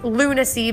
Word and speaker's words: Lunasea: And yes, it's pronounced Lunacy because the people who own it say that they're crazy. So it's funny, you know, because they are a little bit crazy, but in a good way. But it's spelled Lunasea, Alpaca Farm.